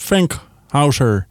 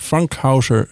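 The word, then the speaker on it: Fankhauser: Philip Frankhauser. Nou ja, dat is een beetje op zijn Engels uitgesproken. Maar eigenlijk komt die man uit Zwitserland. Philip Frankhauser, zou je dan wel zeggen.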